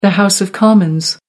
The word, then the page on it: House of Commons: American English